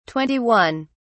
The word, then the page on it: twenty one: British English